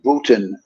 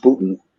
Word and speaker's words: Boonton: that was in Booton, New Jersey, which is spelled B-O-O-N, and then I think T-O-W-N maybe, or T-O-N. It's spelled Boonton, but it's pronounced Booton.